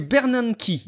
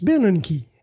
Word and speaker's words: Bernanke: J'ai testé parce que depuis que je prononce mal les choses, on m'a recommandé d'utiliser Google Traduction et d'utiliser le petit haut-parleur. C'est Bernanke. – Bernanke. – Voilà, disons sachons-le, Bernanke.